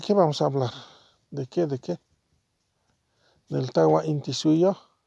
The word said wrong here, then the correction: Tahuantinsuyo: Del Tahuantinsuyo, ¿no? Del Tahuantinsuyo.